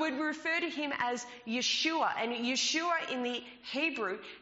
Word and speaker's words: Yeshua: But if we were to take that word directly from the Hebrew into the English, we would pronounce it Yeshua.